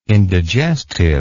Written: indigestive

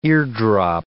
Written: eardrop

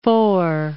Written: Faure

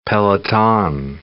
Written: peloton

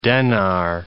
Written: denar

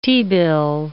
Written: T-bill

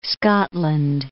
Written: Scotland

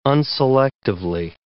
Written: unselectively

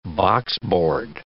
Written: boxboard

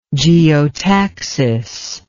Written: geotactically

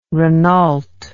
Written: renault

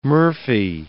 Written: murphy

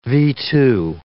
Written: V-2

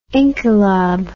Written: inqilab

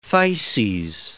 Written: phthises

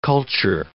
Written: culture

